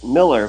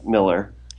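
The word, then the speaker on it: Mueller: So it's Tobin, T-O-B-I-N M-U-E-L-L-E-R. And how do you say your name? – Well, we say it Miller because from where we're from in Germany, it's it sounds Müller as opposed to Müller. So it doesn't sound like Müller. It sounds more like Miller. – Yeah. – And that's how we pronounce it, but it's M-U-E-L-L-E-R.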